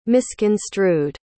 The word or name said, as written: misconstrued